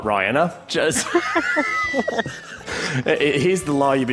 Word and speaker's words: Rihanna: It's Rihanna.